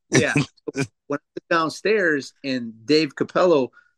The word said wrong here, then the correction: Capullo: or Capullo, however you say his name. – Capullo.